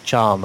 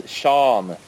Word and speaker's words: Can: Really, I can't give you any names, but this Emre Can, or whatever, however his name is pronounced, yeah.